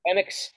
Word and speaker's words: Penix: Penix.